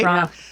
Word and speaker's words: Toronto: And if there's something we know here in Canada is that you do not pronounce that second T. – Toronto. Toronto. – It's Toronto, right? – Toronto.